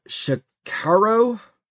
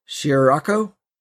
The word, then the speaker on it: Shirrako: In November of 2018, YouTuber Shikaro, I think is how you pronounce it, S-H-I-R-R-A-K-O, Shirako.